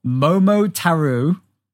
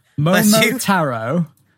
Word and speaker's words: Momotaro: So number four was a game called Momotaro. Oh, sorry, I have butchered that. Momotaro. Yeah.